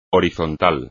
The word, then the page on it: horizontal: espagnol